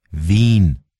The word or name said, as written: Wien